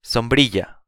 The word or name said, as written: sombrilla